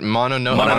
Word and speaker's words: Mononoke: Mononoke.